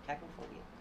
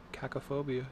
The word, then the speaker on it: cacophobia: Yeah, C-A-C-O. Cacophobia. – Cacophobia, yeah.